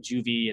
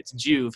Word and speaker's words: Juve: And so Juve, although some people kind of call it Juvie and, you know, sometimes misspell it, it's actually Juve and it's short for rejuvenate.